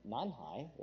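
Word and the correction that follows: nǐ hǎo: right? Am I saying that right? No. Nihao, thank you. Oh, yeah. See, I know this. It's been a while. It's been many, many moons ago. Nihao, we would say, oh yes, and you have your greeting, how are you?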